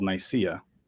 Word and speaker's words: Nicaea: The adjective is Nicene, but the city is called Nicaea. – Yeah, N-I-C-E-A, correct? – Yeah, there's actually an extra.